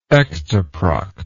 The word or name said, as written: ectoproctan